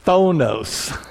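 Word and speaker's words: Thonos (φθόνος): Let me spell that for you. P-H-T-H-O-N-O-S. We're not used to, in our English-speaking world, saying a P-H and a T-H together. Thonos.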